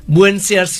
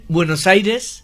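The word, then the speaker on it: Buenos Aires: তার জন্য স্প্যানিশ ভাষাটা অনেক লজিক্যাল, যেরকম লিখছি সেরকম, শুধু কয়েকটা উচ্চারণের তফাত, বুয়েনসিয়ার্স নয় বুয়েনোস আইরেস, ইনফ্যাক্ট আর্জেন্টিনা তার ঠিক উচ্চারণ নয়, আর্জেন্টিনা, জিটা খ বা হ।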